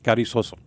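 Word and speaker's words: Carrizozo: And that brings us to your number one most active New Mexico investigation, Roy's Gift Gallery in Carrizo. – Carrizoso. – Carrizoso. – Yes. Yeah. I'm sorry. Did I misspell it there? – No, I mispronounced it here.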